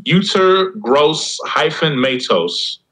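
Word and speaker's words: Yetur Gross-Matos: It's pronounced Uter Gross hyphen Matos.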